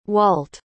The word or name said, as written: walt